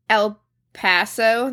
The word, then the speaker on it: El Paso: He was born in El Paso.